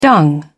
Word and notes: dung: British English

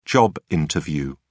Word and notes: job interview: British English

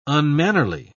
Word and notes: unmannerly: British English

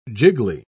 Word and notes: jiggly: British English

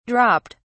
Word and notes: dropped: British English

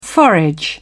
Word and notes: forage: British English

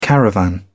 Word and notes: caravan: British English

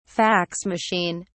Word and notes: fax machine: British English

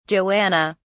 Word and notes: joanna: British English

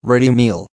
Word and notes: ready meal: American English

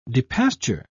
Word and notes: depasture: British English

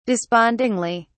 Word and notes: despondingly: British English

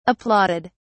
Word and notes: applauded: British English

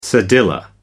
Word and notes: cedilla: American English

